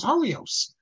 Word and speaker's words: Horayot: and I said it's Yeshivish pronunciation is Horios. So one of you said that, well, how do I know that that's the Yeshivish pronunciation? Maybe that is the actual pronunciation. Because after all, Horios is spelled with a yud. But the truth is that you can't say this at all, because there's no word Horios with a cheruk under the resh.